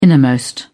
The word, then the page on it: innermost: British English